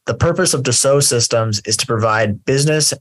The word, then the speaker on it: Dassault: If you're reading the title, it probably looks like Dassault Systèmes. It's Dassault is the proper pronunciation.